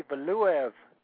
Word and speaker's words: Valuev: Valu? I don't know. How do you pronounce that? – That would be Valuev.